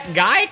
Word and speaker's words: Geick: It's true, y'all, is that Pat Geick rhymes with Dyke, and he sang the census song for the BFO.